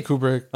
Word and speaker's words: Kubrick: The food writing, and then you went, like you saw the guy who knew you drew Stanley Kubrick. – Yeah, or Kubrick. How do you pronounce it? – I say Kubrick. – Okay, Kubrick. Cool. Kubrick.